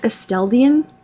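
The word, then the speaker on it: Esteldín: It's Esteldin. – I don't know. – Esteldin. – All I remember is when he first pronounced it to me, he spelled it ass, but less on the ass.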